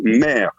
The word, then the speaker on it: Meyre: C'est le directeur d'un château du Médoc qui me dit c'est ça, c'est le château Mère. Ça s'écrit M-E-Y-R-E, si vous voulez voir ce lieu qui est magnifique.